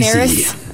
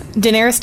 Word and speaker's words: Daenerys: Daenerys. Daenerys Targaryen.